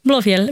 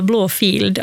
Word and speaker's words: Blåfield: Blå, det som folk brukar tendera att uttala Blåfield, alltså blå, alltså en F-I-E-L-D, för yeah. att det är en gammal stavning av ordet fjäll. Så det namnet är Blåfjäll.